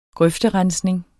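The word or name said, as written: grøfterensning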